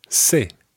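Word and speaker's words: ce: Before we do, there's one other thing that I'd like to say, and that is that in Spain, the letter C is pronounced the, and in Latin America, it's pronounced se.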